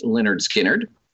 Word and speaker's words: Lynyrd Skynyrd: As we move into 1973, we have Lynyrd Skynyrd, pronounced Lynyrd Skynyrd.